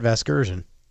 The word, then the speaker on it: Vasgersian: Vergasian? How do you say the guy's last name? Vergasian? Isn't his last name Vergasian? – You know who that is. – That would be my pal Matt Vasgersian.